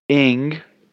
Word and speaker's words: Aang: you got the pronunciation right. Ing, spelled it I-N-G, or Aang, spelled it E-N-G, work. You should hear how it's pronounced in Chinese. Mark, did you get the pronunciation because Kim Aang was the Yankees assistant GM? Now, apparently, Steven has much more tolerance for multiple pronunciations than Alan. So, Alan, I apologize, and Steven, I apologize, and I will make every attempt to pronounce it Ing from now on.